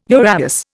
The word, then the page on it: uraeus: Indian